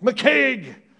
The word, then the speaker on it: McCaig: This was built by a guy named John Stuart McCaig, properly pronounced McCaig, who was a wealthy banker from this town.